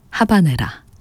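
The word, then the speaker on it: Habanera: 비제 오페라 까르멘 가운데 하바네라, 아바네라 전해드렸습니다. 고티에 카피송의 첼로, 파리 취임보 오케스트라의 연주였습니다. 원어 발음에는 또 아바네라라고도 또 저희가 소개해드리곤 하는데요.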